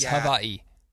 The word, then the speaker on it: Hawaii: That's how they pronounce... That's the correct pronunciation of Hawaii as Hawaii. – Go on. – Hawaii. So everybody Hawaii. Knows out there, say Hawaii. That is how it is correctly pronounced.